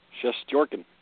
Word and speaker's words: Shesterkin: Shesterkin. – Help me. – Shesterkin. – Shesterkin. Ooh, York. I like that. Like New York.